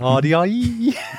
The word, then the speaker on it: Ardiaei: It's spelt A R D I a E I. and I think it's Ardiaei.